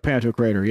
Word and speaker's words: Pantocrator: How do you say it? Pantocrator? – The Pantocrator. – Yeah. – Looks like Pantocrator.